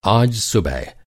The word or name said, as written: आज